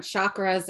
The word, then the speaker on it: chakra: Okay, so first of all, it's pronounced chakra. Like C-H, cha, not. Most people say it as an S-H. Like shah.